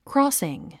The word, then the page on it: crossing: krɔ́ːsiŋ; クロォスィン